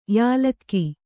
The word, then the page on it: ಯಲ್ಲಕ: yallaka